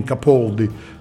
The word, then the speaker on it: Capaldi: di chiara origine italiana, naturalmente la pronuncia inglese diventa Gene Capaldi, scomparso.